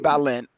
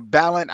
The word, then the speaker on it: Balent: The art is Jim Ballant, or Ballant. I don't know how you pronounce his name. B-A-L-E-N-T. It should rhyme with talent, but he has none.